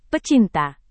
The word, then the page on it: pecinta: Indonesian